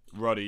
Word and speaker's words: Ruddy: He's playing a young Al Ruddy, or Rudy, did I pronounce it right? – I think it might be Ruddy. – Ruddy, yeah,